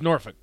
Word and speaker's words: Norfolk: It's pronounced Norfolk. – Norfolk? – Norfolk. Norfolk. – I have to say, it's pronounced differently ever. Norfolk, yeah. Virginia. Norfolk. And those are by far the worst drivers, not only in the U.S., but the whole world. – Norfolk does have some really bad drivers. Really bad. I've never lived in Norfolk, though. – That is a dangerous word. – That's how it's said in Virginia.